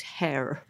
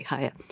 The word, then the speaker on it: Heyer: H-E-Y-E-R, it's variously pronounced, but she herself said it was hair. Okay. I always used to say higher.